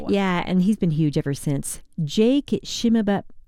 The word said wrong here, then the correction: Shimabukuro: Monique can say his name. How do you say his name? – Shimabukuro.